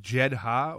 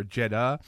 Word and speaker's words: Jedha: Now, forgive me, is it pronounced Jedha or Jeddah? – No, it's Jeddah, like the moon in Star Wars.